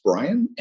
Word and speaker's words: Bryn: My name is spelled B-R-Y-N, that's Brian, and Novoa, N-O-V-O-A.